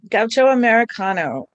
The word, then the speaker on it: Gaucho Americano: Yeah, Gaucho Americano.